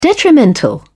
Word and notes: detrimental: British English

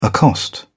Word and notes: accost: British English

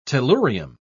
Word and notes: tellurium: British English

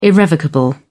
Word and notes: irrevocable: British English

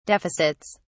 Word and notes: deficits: British English

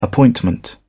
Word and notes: appointment: British English

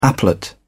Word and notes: applet: British English

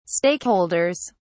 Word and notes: stakeholders: British English

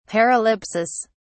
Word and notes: paralipsis: British English

